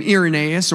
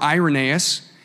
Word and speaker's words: Irenaeus: There's a couple ways you could say his name, Irenaeus or Irenaeus.